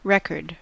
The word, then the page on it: record: American English